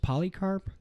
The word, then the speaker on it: Polycarp: Is it just Polycarp? That's how you pronounce it? Yeah, Clement and Polycarp, who have historical written recordings of interactions with the apostles.